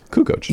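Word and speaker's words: Kukoč: We mispronounced Tony Kukoc's name for, you know, 10 seasons. – Why? What is it? I think you just said it right. Kukoc.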